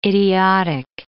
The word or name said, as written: idiotic